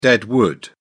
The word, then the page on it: dead wood: British English